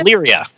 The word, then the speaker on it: Illyria: Ilaria? How do you say it? Illyria.